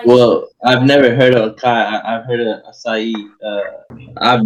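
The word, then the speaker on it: açaí: Like try pronouncing it the way it should be said. That's the way I see it. – What I like to say, like, I feel long, so Akai is cuter, and I like it.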